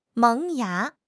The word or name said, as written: 萌芽